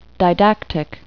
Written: didactic

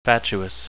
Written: fatuous

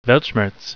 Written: Weltschmerz

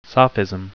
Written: sophism

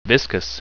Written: viscous

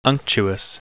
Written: unctuous

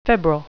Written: febrile

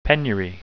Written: penury

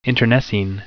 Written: internecine

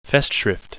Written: festschrift

